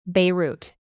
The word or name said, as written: beirut